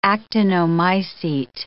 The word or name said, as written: actinomycete